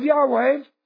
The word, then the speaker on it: Yahweh: Some religious scholars suggest that the name was pronounced Yahweh.